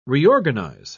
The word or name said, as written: reorganize